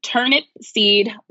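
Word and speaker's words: Turnipseed: It's HILLIARY, last name turnip seed, like the vegetable.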